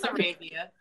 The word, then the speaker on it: Arabia: Yeah, so my name is Arabia. I speak two languages. So in Spanish, it is Arabia. So you get a pass there. You're not wrong. Right pronunciation is the wrong language. But yeah, it's Arabia.